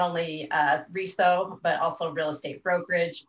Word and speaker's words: RESO: It's an ongoing debate about how you say it, but I prefer RESO, which is also known as the Real Estate Standards Organization.